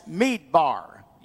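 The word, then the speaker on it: Meedbar (מדבר): By inserting different vowels into this Hebrew word, we pronounce desert as Meedbar. You can spell it then, M-E-E-D-B-A-R, Meedbar, Hebrew word for desert. And speaking is Medaber, M-E-D-A-B-E-R.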